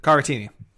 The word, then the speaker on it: Caratini: Caratini.